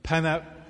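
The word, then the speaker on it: Pen y Fan: But essentially, I got asked to walk up Penny Fan. Is that how you pronounce it? Penavan.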